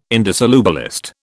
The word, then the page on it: indissolubilist: British English